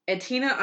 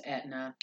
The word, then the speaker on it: Aetna: When Holmes declined to pay the architects or steel company, Aetna Iron and Steel, they sued in 1888. – It's pronounced Aetna.